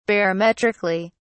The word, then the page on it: barometrically: British English